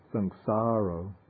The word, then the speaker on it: sangsaro: Then I think where they have sangsaro, I think that should be sangsaro with a long A. Sangsaro.